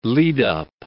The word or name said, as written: lead-up